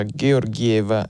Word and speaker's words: Georgieva: il suo cognome si pronuncia Georgieva e non Georgieva come avevo erroneamente detto detto io che ammetto non conosco il bulgaro.